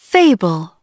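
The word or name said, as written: fable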